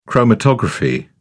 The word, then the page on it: chromatography: British English